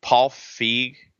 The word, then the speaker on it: Paul Feig: Paul Feig, is that how you pronounce his name? – Yeah.